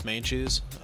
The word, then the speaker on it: Meintjes: So, yeah, hit me up on Facebook, James Keith Mainchus. – How do you spell that last name? – M-E-I-N-T-J-E-S.